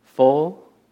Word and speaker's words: foal: and on the," I think I'm saying it right, "foal," or is it foul? Let me ask. Anyone know? I always get that wrong. I think it's a foal, foul? Foal, yeah, okay.